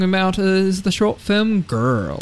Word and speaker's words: Gurl: So, Girl, it's spelt G-U-R-L, because in the old-fashioned days, the gays would go, hey, girl, like that, girl.